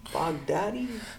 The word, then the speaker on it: Baghdadi: Baghdadi.